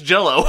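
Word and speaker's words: Jellÿ: And now they go by Green Jelly and just tell everyone it's pronounced Jello.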